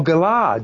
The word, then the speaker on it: galah: Oh, galah. All right, I was pronouncing it wrong.